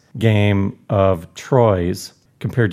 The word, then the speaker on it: Troyes: So the pronunciation of this is Troy. – It's Troy's. T-R-O-Y-E-S. Don't even get me going with these weird pronunciations. I took a lot of flack for this. It is Troy's.